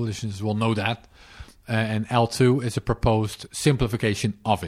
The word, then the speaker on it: eltoo: Yeah, it's spelled ELTOO. – Right. – It's pronounced as L2.